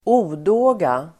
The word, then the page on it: odåga: ²'o:då:ga